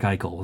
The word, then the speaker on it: Eichel: Eichel is how you say it? – Jack Eichel, yes. – It's a word with consonants that don't look like they should be there. – Yes, Jack Eichel.